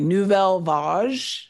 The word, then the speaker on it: Nouvelle Vague: Yeah, because they had and you can pronounce this better than I can, Nouveau Vague. – I've pronounced that name so many, or mispronounced it so many times. I hope I have it right. Nouvelle Vague, There you go.